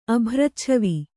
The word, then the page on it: ಅಭ್ರಚ್ಛವಿ: Kannada